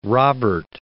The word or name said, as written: Robert I